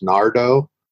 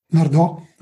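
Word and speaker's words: Nardò: I believe it's pronounced Nardo. – Nardo, okay.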